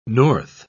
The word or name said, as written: north